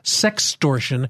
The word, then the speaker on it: sextortion: And I mispronounced it because I think I misspelled it in my notes, but it's like the word extortion. – Mm. with an S in front of it. So it's Sextortion.